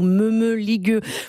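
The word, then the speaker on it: mămăligă: Voilà donc Mamaliga ou Meumeligue pour le prononcer à la romaine de Zoé Barthélémy.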